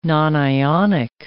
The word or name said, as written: nonionic